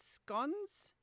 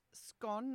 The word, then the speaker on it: scone: We call biscuits scones. That's pronounced scone, not scone.